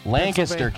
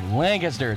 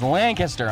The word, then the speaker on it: Lancaster: We're going to be at Orange Tour in Lancaster. Lancaster. It's pronounced Lancaster. I'm going to say it. It's Lancaster.